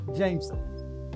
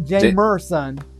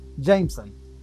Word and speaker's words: Jameson: Jameson. Not Jamerson. Jameson. – Jameson. – What? Spell it. – Jamesson. – Where's the R? – I didn't say an R.